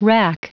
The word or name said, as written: wrack